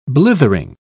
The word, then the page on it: blithering: British English